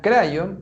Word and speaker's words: Kryon: Ya, Crayon, que se escribe K-R-Y-O-N, esta es una entidad,